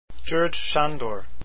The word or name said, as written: sandor